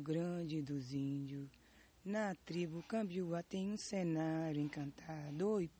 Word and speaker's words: Kambiwá: Kambiwá. – C-A-M-B-I-U-A. Kambiwá. – K-A-M-B-I-W-A. – M-B-I-W-A.